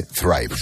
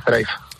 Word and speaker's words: Zrive: Hablamos ahora, es el CEO de, a ver cómo lo digo, Zrive, se dice Thrive, supongo. ¿qué tal? Buenas noches. – Hola, buenas noches, y muchas gracias por la invitación. Y correcto, ¿cómo has pronunciado el nombre? Thrive. – Thrive con Z. Zrive, escrito para que nos entendamos.